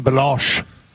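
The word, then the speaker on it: Balekji: No, they call him Belosh. – Belloc.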